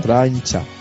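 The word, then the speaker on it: Trijntje: Je n'ai toujours pas compris comment on prononce son prénom. – Traincha. – Traincha, voilà. Alors, ça s'écrit T-R-I, il y a des J, des N, des T, c'est compliqué.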